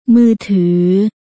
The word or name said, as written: มือถือ